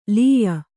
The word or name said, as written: ಲೀಯ